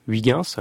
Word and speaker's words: Huygens: Oui, on avait envoyé donc la mission Cassini, et donc on avait posé déjà une sonde qui s'appelait Huygens. Alors les néerlandophones vont me dire que c'est pas comme ça qu'on le prononce, mais c'est comme ça qu'on le prononce.